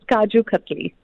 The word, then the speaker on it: kaju katli: And you've got a pistachio kuju cutli. Have I said that right? Please do not be offended. – No. No, you haven't, but it's kaju cutli.